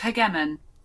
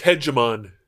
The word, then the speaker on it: hegemon: These were the periods in which England and then America filled the role of hegemon. – Hey Google, that's pronounced hegemon and hegemony. – That's pronounced hegemon. – Hey Google, it's pronounced hegemony. – That's pronounced hegemony.